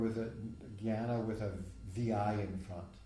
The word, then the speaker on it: jnana: In Sanskrit, there's two words, jnana, jnana, really, if you pronounce it correctly, we say jnani because we're speaking like Bengalis or Hindis, but it's jnana.